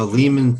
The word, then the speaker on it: Lehman: Was it Lehman or Lehman? What, you, what is it? I mean, it's been so long. How do you pronounce it? Uh, Lehman for sure. Lehman, Lehman. All right, Lehman.